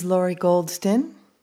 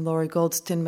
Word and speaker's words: Lori Goldston: This is Laurie Goldston. I'm here with Judith Hamann, who's visiting from San Diego and Melbourne, both playing cello. Judith's last name is spelled H-A-M-A-N-N. I'm Laurie Goldston. My name is spelled L-O-R-I. G-O-L-D-S-T-O-N.